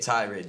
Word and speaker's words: tirade: Yeah, Tirade. That's how you say the word.